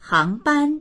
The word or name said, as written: hang2ban1